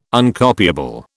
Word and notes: Uncopiable: British English

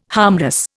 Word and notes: harmless: Indian

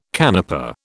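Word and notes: canapé: British English

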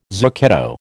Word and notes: zucchetto: American English

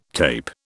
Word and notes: tape: British English

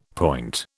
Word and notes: point: British English

